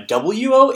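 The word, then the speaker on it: wo (を): That wo sound, the W sound at the beginning, indicates the funny little thing that looks like a T and a C, kind of had a baby.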